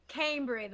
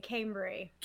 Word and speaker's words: Cambry: Cambry, the long A, Cambry. – Cambry. See, yeah. I knew I should have pronounced that a little bit better. – Cambry King, and everybody thinks that's a stage name, but that is my birth given name, is the last name King.